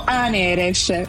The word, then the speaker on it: Aneres: Esatto, Aneres. – Aneres, sì. Aneres, quindi tu vai con l'accento sulla E.